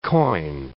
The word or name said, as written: coin